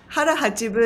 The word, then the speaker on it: hara hachi bu: And using these concepts like, so I call it harahachibu. Is that how did you pronounce it? It's harahachibu is also one way you can say it. I said harahachibu, but yeah.